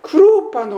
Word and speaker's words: クロパ: クレオパというのは、ユダヤ読みにいたしますとクロパというふうになるんだそうでありまして、多分夫婦かな、そんなふうに思うわけですね。